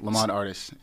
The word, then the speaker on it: Lamont Artis: Lamont Artis.